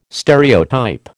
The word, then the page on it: stereotype: American English